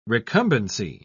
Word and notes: recumbency: British English

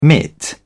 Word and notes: mitt: British English

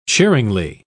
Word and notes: cheeringly: British English

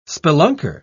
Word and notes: spelunker: British English

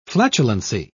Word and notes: flatulency: British English

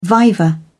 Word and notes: viva: British English

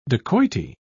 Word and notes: dacoity: British English

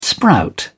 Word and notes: sprout: British English

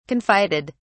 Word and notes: confided: British English

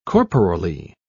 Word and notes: corporally: British English

